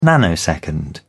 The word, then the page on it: nanosecond: British English